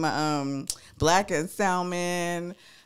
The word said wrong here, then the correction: salmon: Did you just pronounce the L on that? – Salmon. Okay, salmon.